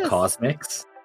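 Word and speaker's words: CosMc's: McDonald's is opening up something called Cause MCs, which is going to be like their beverage shops. Now, people have rumored that it's actually supposed to be pronounced Cosmics, but I like Cause MCs.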